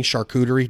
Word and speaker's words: charcuterie: Love and I'm not real good at pronouncing charcuterie. Did I pronounce that right? – Yeah, you nailed it.